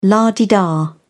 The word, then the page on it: la-di-da: British English